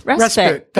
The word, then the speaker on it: respite: Is it respite? Respite? – Yeah, respite.